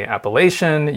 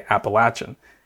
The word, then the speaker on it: Appalachian: Yeah, so as you may know, there's a couple of ways to pronounce the name of our region. You can say Appalachian, you can say Appalachian, and where we grew up in Western Maryland, more people tend to say Appalachian.